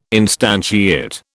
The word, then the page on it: instantiate: British English